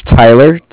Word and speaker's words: Tyler: Okay, my name is Tyler, T-Y-L-E-R, and I'm calling with Cardiff. It's spelled C-A-R-D-I-F-F, as in Frank.